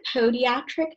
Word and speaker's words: podiatric: So our first question for you is, how did you decide that you wanted to pursue DPM, which stands for Doctor of Pediatric Medicine? Am I saying that right? – I pronounce it podiatric medicine, but I mean, it's fine.